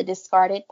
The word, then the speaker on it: Discard It: So my podcast is called The Discarded Podcast. And it's spelled discard it, I-T, but pronounced discarded.